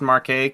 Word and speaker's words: Marquette: I'm assuming it's Marquette, because if you pronounce the T's, you're a bitch.